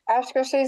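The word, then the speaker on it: Akershus: So is breakfast at, how do you say that? – Oscar Seuss, is it still one? – Because yes.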